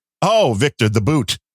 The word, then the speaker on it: Bout: Well, at least you pronounce his name correctly. Unlike 99% of the media. – Then what are they calling him? – Bout. – Yeah, because B-O-U-T is the American spelling, but it's not spelled that way in Russian. It's spelled boot.